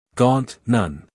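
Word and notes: gaunt: uk ɡɔːnt; en-us ɡɔːnt

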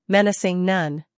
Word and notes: menacing: ˈmenəsɪŋ

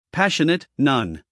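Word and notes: passionate: uk ˈpæʃənət; en-us ˈpæʃənət

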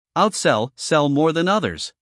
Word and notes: outsell: ˌaʊtˈsel

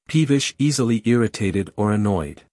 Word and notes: peevish: ˈpiːvɪʃ

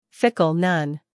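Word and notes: fickle: uk /ˈfɪk(ə)l/; en-us /ˈfɪk(ə)l/

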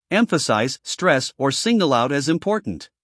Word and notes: emphasize: ˈemfəsaɪz

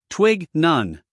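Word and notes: twig: uk /twɪɡ/; en-us /twɪɡ/

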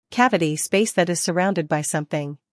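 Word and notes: cavity: uk ˈkævəti; en-us ˈkævəti

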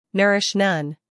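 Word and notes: nourish: uk ˈnʌrɪʃ; en-us ˈnɜːrɪʃ

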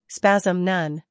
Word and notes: spasm: ˈspæzəm

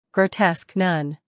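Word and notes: grotesque: uk ɡrəʊˈtesk; en-us ɡroʊˈtesk

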